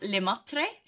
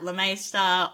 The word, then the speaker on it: Le Maistre: So my last name is pronounced Le Maistre. But in Australia, a lot of people just say like Le Maistre, or, you know, there's the master, which is what it means in English.